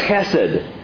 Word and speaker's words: chesed: The great Hebrew word translated kindness here is chesed. Chesed. If you spell it out, if you're writing an old transliteration, spell it with a CH. It's an H in the Hebrew, but it makes that sound.